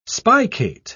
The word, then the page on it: spicate: British English